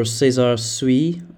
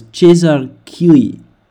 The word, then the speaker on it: César Cui: Actually, we've got a pronunciation here, it's César Cui. César Cui, that's how you say it. Okay, not César Suy. It is rather a kind of French name, but it's, I believe it's César Cui.